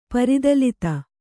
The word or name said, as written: ಪರಿದಲಿತ